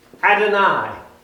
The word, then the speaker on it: Adonai: E-L-E-L-Y-O-N. El Elyon. The next one is Adonai. That's A-D-O-N-A-I.